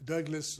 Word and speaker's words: Douglas: Douglas Augustus Miller.